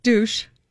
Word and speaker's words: douche: How do you say, how would you say the word, the D word in French? – Douche. – Oh, you would? – Yeah. – Okay. – That's how you say it.